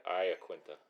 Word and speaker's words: Iaquinta: And we are going to talk about all things UFC Al Iaquinta. Is that how you said it right? – Yeah, Iaquinta.